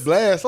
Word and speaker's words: Blxst: You pronounce it Blast. It's BLXST.